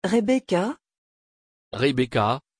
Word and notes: rebekah: French

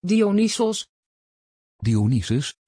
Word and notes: Dionysos: Dutch

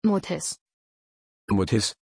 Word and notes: Motis: German

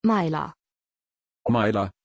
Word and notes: Maila: German

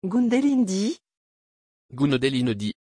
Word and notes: gundelindis: French

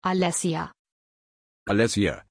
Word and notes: Alessia: German

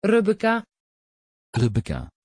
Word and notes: rebekka: Dutch

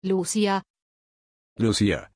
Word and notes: Lucía: German

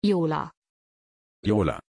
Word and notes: iola: German

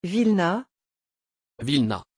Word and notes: vilena: French

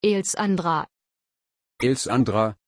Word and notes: Alesandra: German

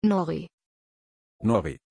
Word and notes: Nori: German

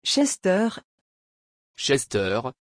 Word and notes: chester: French

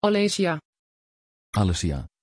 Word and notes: Alessya: Dutch

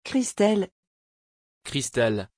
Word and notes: christel: French

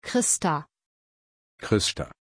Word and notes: christa: German

